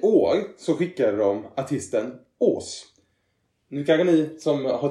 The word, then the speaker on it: Wrs: Men det är så man uttalar hans eh, artistnamn som stavas WRS.